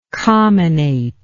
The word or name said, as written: comminate